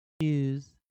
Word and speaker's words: shoes: 所以你看到 o e 可以发 o 或者 u 啊，toes，toes，toes，shoes，shoes，shoes。所以在这个地方看起来都像 o e 结尾，有复数的发音的方式。再来一遍，toes。 toes, toes, shoes, shoes, shoes, goes, goes, goes, does, does, does。